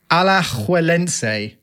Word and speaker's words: Alajuelense: Deportivo Alajuelense from Costa Rica.